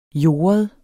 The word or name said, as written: jordet